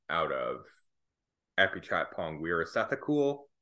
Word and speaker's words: Weerasethakul: Apichatpong Weerasethakul. – Weerasethakul.